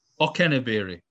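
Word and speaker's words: Okenabirhie: and I'm going to pronounce this incorrectly, but I believe it's Onikaberi is the name. – Okeneberi. – Okeneberi. I was close.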